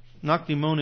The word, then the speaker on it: Nakdimon: Nakdimon is how you say Nicodemus in Hebrew.